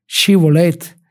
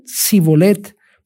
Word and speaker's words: Shibolet: Si él respondía que no, entonces le decían, ahora pues di Shibolet. Y si decían Sibolet, porque no podían pronunciarlo correctamente,